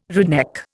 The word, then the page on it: redneck: Indian